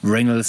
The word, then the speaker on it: Wrangell: Wir sind jetzt gerade im Wrangell St. Elias National Park oder auf Englisch richtig ausgesprochen Wrangell St. Elias National Park.